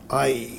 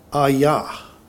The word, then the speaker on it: Ai: That's an interesting name for a town, Ai. It's spelled A-I. Alternate pronunciation will be Ayah.